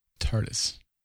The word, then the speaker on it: TARDIS: It's going to be epic, Tidus. – TARDIS. Say it right.